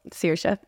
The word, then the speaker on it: Saoirse: Saoirse. – Mm-hmm. Saoirse.